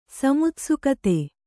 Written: ಸಮುತ್ಸುಕತೆ

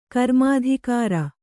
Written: ಕರ್ಮಾಧಿಕಾರ

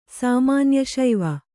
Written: ಸಾಮಾನ್ಯಶೈವ

